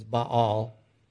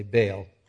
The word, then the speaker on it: Baal: Now, I have a hunch that Balaam, and Balak for sure, King Balak of the Moabites for sure, was a Baal worship. Some people pronounce that idol as Baal. It's easier for me to say Baal, because if Baal comes around, you need to bail.